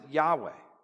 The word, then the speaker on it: Yahweh: Specifically, it's the four letters, YHWH, and you pronounce it Yahweh.